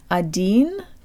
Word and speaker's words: один: These subtle details are what can transform один to один.